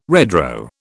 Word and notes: redraw: British English

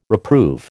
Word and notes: reprove: American English

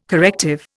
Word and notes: corrective: Indian

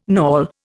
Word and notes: knawel: Indian

